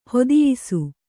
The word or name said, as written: ಹತಿಯಿಸು